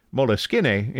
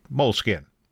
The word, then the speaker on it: Moleskine: Oh, and by the way, whether you pronounce the name of the company as Moleskine or Moleskine may depend on your knowledge of Italian. Moleskine is the Italian pronunciation, but many in English-speaking countries just say Moleskine. That's the Anglo pronunciation.